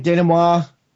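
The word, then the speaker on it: dénouement: Denouement.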